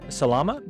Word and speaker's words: Salama: Today, I'm joined with life coach Martin Salama. Did I pronounce that right, Martin? – Perfectly.